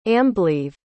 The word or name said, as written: amblaoibh